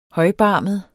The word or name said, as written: højbarmet